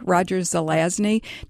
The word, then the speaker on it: Roger Zelazny: Yeah, I was just wondering if Nancy listened or read too much Roger Zelani. – Oh, yes. – Am I pronouncing that right? – I've always said Zelazny, Roger Zelazny, Nine Princes in Amber.